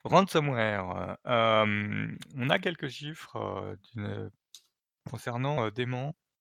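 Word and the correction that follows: Demant: qui est, je vois comment ça se prononce, Demand,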